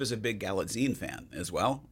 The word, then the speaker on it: Galitzine: With starting with, and I want to make sure I get the name right, Nicholas Galitzine playing He-Man slash Prince Adam.